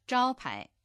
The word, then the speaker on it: 招牌: 招牌。 – 招牌。 – 招牌の招は第一声、牌は第二声です。